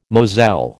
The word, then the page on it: moselle: American English